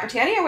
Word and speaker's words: Brittania: And you can follow me at Britannia, which is BRITTANIA with an underscore at the end, and I almost spelled my own name wrong.